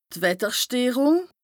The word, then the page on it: d' Wettersteerùng: Bas-Rhin; Strasbourg